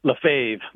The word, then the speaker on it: Lefebvre: Is it Lefebvre? – Lefebvre. Well, that's how we say it. I mean, I don't know if we're pronouncing it correctly, but that's the way we've been doing it.